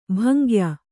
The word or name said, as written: ಭಂಗ್ಯ